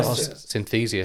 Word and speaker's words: Synthesia: Oh, Synthesia.